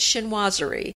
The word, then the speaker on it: chinoiserie: It's chinoiserie.